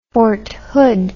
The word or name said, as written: fort hood